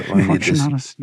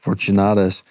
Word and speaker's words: Fortunatus: Fortunatus. – Fortunatus.